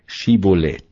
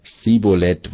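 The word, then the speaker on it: Schiboleth: Wenn er dann antwortete: Nein, ließen sie ihn sprechen: Schibolet. Sprach er aber Sibolet, weil er's nicht richtig aussprechen konnte, dann ergriffen sie ihn und erschlugen ihn an den Furten des Jordan, so daß zu der Zeit von Ephraim fielen 42.000.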